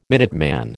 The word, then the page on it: Minuteman: American English